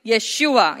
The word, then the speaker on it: Yeshua: So when the man claiming himself to be the Son of God, coming in the name of Yeshua, Jesus in English, but Yeshua, Matthew 1:21, it says he will save his people from their sins, and the original pronunciation is Yeshua. Say with me, Yeshua.